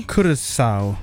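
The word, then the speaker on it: Curaçao: So that is Germany and, let's see, is it Curacao? Am I pronouncing that correctly? – Curacao. – Oh, Curacao. Okay.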